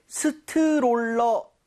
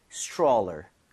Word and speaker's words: stroller: 자, 근데 발음할 때 스트롤러 아 이건 좀 아닌 것 같아요. – 아쉽네요. – 이 모양을 좀 많이 모아서 R 사운드 제대로 돼서 약간 치읓 비슷하죠. 스트롤러처럼 네. – 살짝 변하게 여러분들 이 모양 모으시고 혀 많이 당겨주세요.